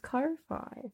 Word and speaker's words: Ko-fi: I thought it was pronounced Ko-fi. – Ko-fi, coffee.